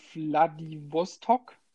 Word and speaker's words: Vladivostok: Vladivostok.